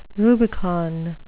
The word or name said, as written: rubicon